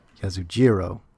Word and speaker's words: Yasujiro: Directed by, how do you say his first name? Yasuhiro? Yasujiro Ozu.